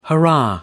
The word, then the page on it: hurrah: British English